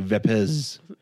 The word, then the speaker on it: Vapez: V-A-P-E-Z. – Yeah, it's Vapes with the Z. And I was But it's like you can also say it like Vapez.